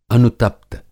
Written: अनुतप्त

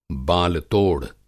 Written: बालतोड़

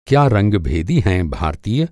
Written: रंगभेदी

